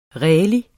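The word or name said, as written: RÆLIG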